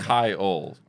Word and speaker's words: Kyle: But yeah, Kyle is just super easy to remember. It's very easy phonetically. It's Kyle.